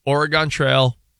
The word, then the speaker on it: Oregon: And you mean Oregon Trail? – What I say? – Oregon. – Isn't that how you say it? – No. – No. – Oregon Trail.